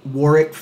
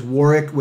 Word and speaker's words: Warwick: Uh, you can engage us on uh, LinkedIn at Warwick Fairfax, and that is Warwick with the silent W in the middle, W A R W I C K, at Warwick Fairfax.